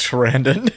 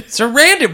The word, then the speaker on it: Sarandon: But so he calls Jerry, who is our vampire played by Chris Sardonis. – Chris Sarandon. – Sarandon.